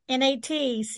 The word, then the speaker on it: Natchitoches: It's not spelled the way it's pronounced. So, yep, Natchitoches, Natchitoches.